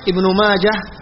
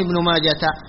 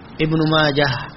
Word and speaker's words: ابن ماجه: ابن ماجه إلى. أما كلمة ابن ماجه فتنطق بالهاء وصلا ووقفا، يعني لا تكون ابن ماجة أو تو، لا لا، ابن ماجة ها ما فيها نقطتين، وصلا ووقفا هكذا اسمه.